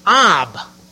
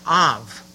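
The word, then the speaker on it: Av (אב): See, beloved, the Hebrew word for father is Ab. A-B. Ab. Or more correctly pronounced, Av.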